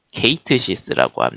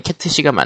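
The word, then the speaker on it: Cait Sith: 파이널 판타지 7 리버스 그러니까 리메이크의 후속작이 트레일러가 계속 공개가 되고 있는데 캣트시라고 알려져 있던 캐릭터가 사실 케이트 시스라고 합니다. 시스. 시스. 그것은 시스 그것은 아크사이드의 증표죠. – 그러니까 일본어로는 캐트시가 맞는데 여, 여, 다른 글로벌 영어는 케이트시스로 바, 발음을 확정해 버렸다고 하네요.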